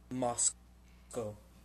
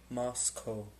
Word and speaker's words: Moscow: Moscow. Moscow. So it's not like cow. It's Co.